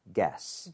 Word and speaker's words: guess: 여기서는 어떻게 보면은 you가 묵음이라고 생각을 해야 돼요. 그래서 구에 이렇게 가지가 않죠. 무조건 guess. – guess. – guess가 맞는 발음이고요.